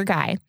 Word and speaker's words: Geye: So Peter Guy's last name is spelled G-E-Y-E. So Peter Guy.